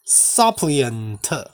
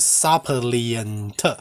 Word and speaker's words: suppliant: 那我刚刚讲的那个 suppliant，它的发音不一样，它是 suppliant，它也是恳求的人，所以恳求的人这个字就有两个字跟两个发音。我再重复一次哦，一个叫做 suppliant，S U P P L I A N T，suppliant，跟另外一个叫做 supplicant。